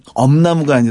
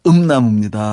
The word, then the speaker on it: 음나무: 그게 사실은 엄나무가 아니라 음나무입니다.